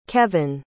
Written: kevin

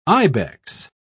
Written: ibex

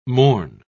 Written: morn

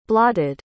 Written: blotted

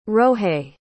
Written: rohais

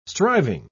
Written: striving

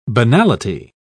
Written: banality

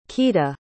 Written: quita

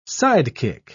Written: sidekick

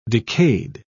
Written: decayed

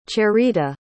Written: cherita

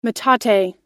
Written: metate

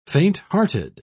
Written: fainthearted

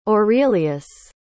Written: aurelius

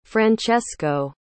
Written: francesco

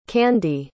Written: Kande

